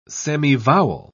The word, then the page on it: semivowel: British English